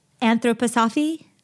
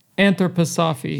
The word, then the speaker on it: Anthroposophy: Can you say the word one more time so I know how to say it? – Anthroposophy? – Anthroposophy. – Yeah. Anthroposophic.